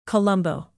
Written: colombo

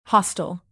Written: hostile

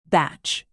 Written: batch

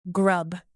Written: grub

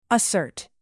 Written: assert

